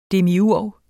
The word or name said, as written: demiurg